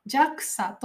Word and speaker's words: JAXA: これは JAXA と読みます。